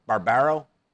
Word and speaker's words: Barbaro: This was a great article by Dr. Barbaro, Ryan Barbaro, out of Michigan. Did I say it wrong? Hello? No. Magic? Did I say it wrong? The name? Barbaro? No.